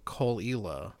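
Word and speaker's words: Caol Ila: The one that is most likely is this one called Coleila. I can't remember how to spell it, but I think the first word is C-A-O-L. – It's probably not spelled anything like it's pronounced.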